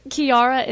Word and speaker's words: Kiara: it's Kiara is K-I-A-R-A, and the last name is spelled P as in Peter, E-L-I-S-S as in Sam, I-E-R, glass, Kiara Policia.